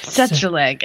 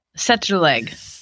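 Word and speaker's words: Tsetserleg: Setzerleg. And how do you say it? – Setzerleg. – Setzerleg. – Yeah, the T is silent.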